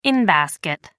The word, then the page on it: in-basket: American English